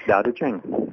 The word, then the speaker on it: Tao Te Ching: That's right, The Activist Tao Te Ching.